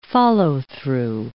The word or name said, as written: follow-through